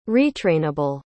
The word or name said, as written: retrainable